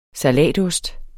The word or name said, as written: salatost